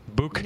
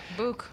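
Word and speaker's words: Bouc: Book. – Yeah, Book. Let's go with Book.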